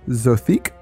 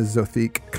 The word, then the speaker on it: Zothique: And there's a whole collection of Clark Ashton Smith's Zothique stories. I believe that's how it's pronounced.